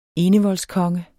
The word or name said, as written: enevoldskonge